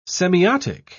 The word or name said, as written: semiotic